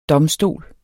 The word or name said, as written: DOMSTOLENS